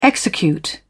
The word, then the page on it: execute: British English